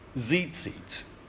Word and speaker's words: tzitzit: In Hebrew, it is called, it's hard to pronounce because it's like T-Z, T-Z. It's one of those tongue twister words. Tzitzit.